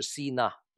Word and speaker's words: Sinai: 在希伯来语实质经济的意思，而且就是西奈这次的字根就跟西奈山一样，在希伯来语西奈的发音是 Sinai，意思是多次多经济的。